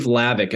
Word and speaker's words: Hlavac: My name is Steve Hlavac. It's spelled Hlavac. The H is silent, which is tricky.